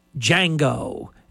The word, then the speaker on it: Django: Django, and the J is silent.